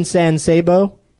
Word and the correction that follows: San Saba: San Saba? No. – Oh, Alec Baldwin not wrong in San Saba. For a whole week now, I've been pronouncing it wrong.